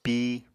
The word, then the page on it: pi: Apatani